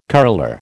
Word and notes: curler: American English

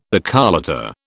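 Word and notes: décolleté: British English

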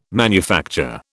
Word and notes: manufacture: British English

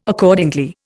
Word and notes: Accordingly: Indian

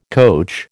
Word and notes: coach: American English

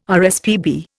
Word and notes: RSPB: Indian

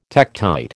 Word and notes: tektite: American English

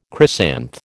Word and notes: chrysanth: American English

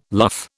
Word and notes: luff: British English

